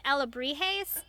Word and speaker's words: alebrijes: And, like, down to, like, even the alebrijes, am I saying that right? – Yes.